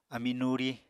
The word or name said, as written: ami nuri